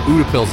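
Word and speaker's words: Utepils: I think the way you got to look at it is it ends with pills. – Okay. – The beer style. So I think, and just remember pills. – The website has the pronunciation on it. – Okay. – Utapils, I believe, would be Utapils. – The closest.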